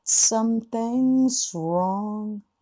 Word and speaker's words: something's wrong: NG っていうのはグと発音したいので、 but something's wrong となります。 but something's ってやらないと。大体日本人の人だとグスウォングとなってる場合が多いんだけども、グを言わずに something's wrong で、 Z は必ずずっと発音してください。